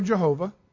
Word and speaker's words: Jehovah: The Hebrew name, pronounced Yahweh or Jehovah, means Lord.